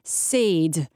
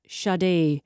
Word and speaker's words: Sade: I pronounced Saed instead of Sade earlier on, and some people were not happy, but it has got us now discussing mispronounced names.